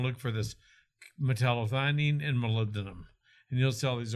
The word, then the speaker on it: molybdenum: So molybdenum, is it, am I saying that right? – Molybdenum, yeah.